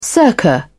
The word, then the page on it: circa: British English